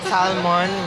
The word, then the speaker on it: salmon: It's salmon with silent L. It's salmon. Hindi siya salmon, S-U-M-M-O-N.